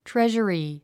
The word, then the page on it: treasury: tréʒəri; トレジャリィ